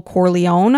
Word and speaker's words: Corleone: But one of the main characters is Michael Corleone, and I'm hoping I'm saying that properly. – You are, that last that.